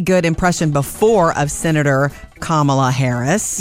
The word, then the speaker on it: Kamala: And it reads, my first name is pronounced Kamala, like the punctuation mark. So it's like comma. – Yeah. – Kamala.